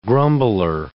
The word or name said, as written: grumbler